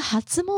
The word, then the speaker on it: 初: 到好像二月中左右吧，这一段期间呢，就是拉长他们这个哈兹莫德的期间。嗯，好，那讲到哈兹莫德，它的汉字写成初哈，初始的初。嗯，那呃，这个初就念作哈兹。